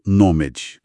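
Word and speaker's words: gnomAD: Tolkovsky e, claro, todos os colaboradores do consórcio Genome Aggregation Database, mais conhecido como NOMED, pronunciado NOMED.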